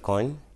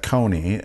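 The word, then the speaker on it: Kony: Led by Coyne, Joseph Coyne. – And we here in America pronounce that Coney.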